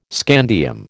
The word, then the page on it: scandium: American English